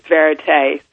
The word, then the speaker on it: Verite: It is Verite. So it's a fictional word that we've based on the actual word Verite, which means, in Latin, means truth.